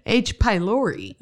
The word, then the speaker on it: H. pylori: Except and I was like, H. pylori. – Uh huh. You added a syllable. – What is it? – H. pylori.